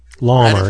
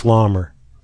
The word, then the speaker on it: Laumer: Keith Lemire, L-A-U-M-E-R. What is it? Retief? – Lamer. Right. Yeah, Keith Lamer. – Right. – I think it's Lamer.